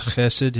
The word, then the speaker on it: chesed: I really enjoyed your section on God's chesed, his covenant faithfulness.